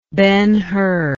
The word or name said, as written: Ben-Hur